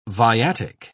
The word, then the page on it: viatic: British English